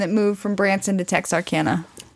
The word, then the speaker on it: Texarkana: Like, there was like Texarkansas, something. – Texarkana. – Thank you. Every time I try to say that, I never know how to say it.